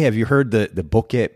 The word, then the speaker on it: bokeh: It is technically bokeh. We pronounce it bokeh for ease of use.